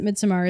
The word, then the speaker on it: Midsommar: The reason we pronounce it Midsommar is that's how Ari Aster pronounces it. – Yeah. – So not a pretentious thing, just a, that is how the director of the film pronounces the title of the film.